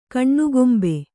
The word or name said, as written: ಕಣ್ಣುಗೊಂಬೆ